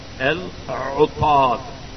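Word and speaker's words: Al-Utaf (العطاس): Al-Utaf. Al-Utaf is sneezing, and it's spelled Aleph Lam Ain Ta Aleph Seen. Al-Utaf with a dhamma on the Ain and a fatha on the ta.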